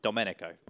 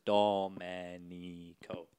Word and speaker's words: Domenico: Domenico. Yeah, because there are no diphthongs in Italian. So it's Domenico.